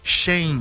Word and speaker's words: Shein: Eu falei certo o nome da empresa? É Shein. Como é que fala? Shein, não é, é isso? Shein, desculpa. AliExpress, né? Tá certo. Foi bem. – Não, mas a primeira é Shein. É, Shein.